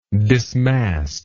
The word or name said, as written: dismastment